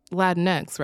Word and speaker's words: Latinx: Wait, that's that's pronounced Latinx, right? Yeah, which I I assume Bari Weiss knows, but honestly, I didn't stick around long enough to find out.